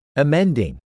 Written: amending

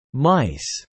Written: mice

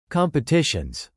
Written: competitions

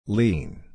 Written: lean

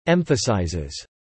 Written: emphasizes

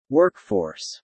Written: workforce

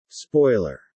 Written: spoiler

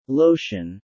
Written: lotion

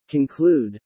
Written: conclude

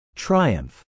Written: triumph

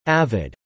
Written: avid

